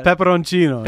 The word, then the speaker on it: pepperoncino: How do you say it? Pepperoncini? – Pepperoncino. – Pepperoncino, yeah.